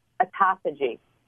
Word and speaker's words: autophagy: Autophagy. – You got to spell it. – I think it's A-T-O-P-H-O-G-Y. – I got to look it up. It sounds like esophagus.